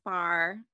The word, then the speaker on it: Farah: I'll say Farah, and they'll say, "oh, so what is that?" So I've gotten that from Caucasian Midwestern women before. And in reality, my name is not hard to say. It's Farah.